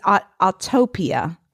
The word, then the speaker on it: Autopia: It's Autopia.